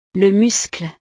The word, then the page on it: le muscle: frances